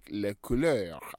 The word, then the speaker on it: les couleurs: Les couleurs.